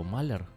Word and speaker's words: маляр: Ударение, я запутался, какое надо ставить. Что-то забыл, вылетело мне с головы. Маляр, да, или маляр? – Маляр.